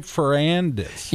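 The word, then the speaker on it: Ferrandis: Am I saying that right? Ferrandez. – Yes, Ferrandez. That's it. Yeah.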